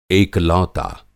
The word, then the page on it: एकलौता: Hindi